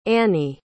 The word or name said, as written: anie